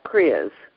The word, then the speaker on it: Kriya: I'm going to spell it K-R-I-Y-A, cleansing exercises. First of all, how do you say it? And then what is it? – Yeah. So it's pronounced Kriya.